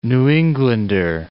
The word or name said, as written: New Englander